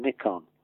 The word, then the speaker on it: Nikon: This box came, I opened the box, and there is a leather box with etched into the top, N-I-K-O-N, Nikon, or Nikon as the Americans say.